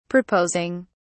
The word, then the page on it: proposing: British English